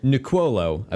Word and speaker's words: Nicuolo: Nicuolo, I believe is how it's pronounced.